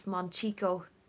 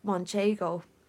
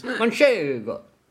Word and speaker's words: Manchego: One of the things she called out was Monchico, which is actually Monkey. – Manchego, when pronounced correctly, and she called it Monchico.